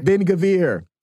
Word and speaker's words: Ben-Gvir: And I think the guy's Itamar Ben-Gvir. I think that's his name. Did I say that right? – Did I say it right? – Ben-Gvir.